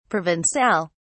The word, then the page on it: provencal: British English